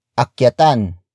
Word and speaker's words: akyatan: Akyatan mo ng ligaw si Stella.